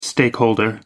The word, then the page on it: stakeholder: American English